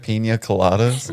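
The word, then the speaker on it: piña colada: And I said, I never knew that you like pina and coladas. – Did you say pina and coladas? – How do you say it? – Pina coladas? – Okay.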